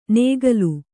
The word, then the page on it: ನೇಗಲು: Kannada